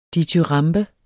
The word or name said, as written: dityramben